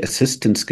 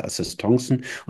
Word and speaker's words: Assistance: Und dieses Wort Assistance wird wie Assistance geschrieben, aber es wird französisch ausgesprochen.